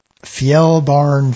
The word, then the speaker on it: Fjällbarn: And I want to pronounce this properly. Fjällbarn Fund. Do I have that right? – Yeah, you okay. Got it. Nailed it.